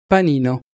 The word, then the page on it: panino: Italian